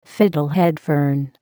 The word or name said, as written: fiddlehead fern